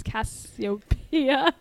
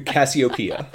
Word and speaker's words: Cassiopeia: It's Cassiopeia. – Cassiopeia.